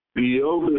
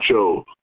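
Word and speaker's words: Yov (איוב): Yov is spelled Yov, not Job.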